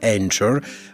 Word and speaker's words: Anchor: scritto con il CH, eh, credo che la pronuncia inglese corretta sia Anchor, ma ah, vabbè, non ci perdiamo in questi particolari linguistici.